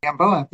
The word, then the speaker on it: Gamboa: Gamboa. Yeah, that's right. Gamboa.